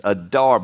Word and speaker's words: Adar: I looked up the pronunciation. It's Adar, but you're supposed to roll the R, and I can't. So it's Adar.